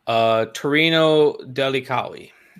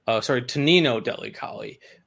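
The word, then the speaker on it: Tonino Delli Colli: Oh, that was, I said it in my intro, Torino Delicali. – Mm-hmm. – I'm probably mispronouncing that, but Tonino Delicali.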